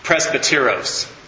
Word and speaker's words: presbyteros: The elder is presbyteros, is how you say that in Greek, and that's where we get Presbyterian.